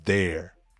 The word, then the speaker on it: there: Então você fala they mais are contraiu, fica there. They're there. Eles estão ali. They're there. They're there. E também o adjetivo possessivo, né? Their car, their business, é a mesma coisa, mesmíssima pronúncia.